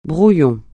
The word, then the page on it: brouillon: francais